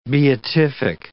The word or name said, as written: beatific